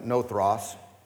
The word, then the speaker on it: nothros: Now, first of all, the word sluggish here, translated from a Greek word that is pronounced like nothros, but you probably don't care about that.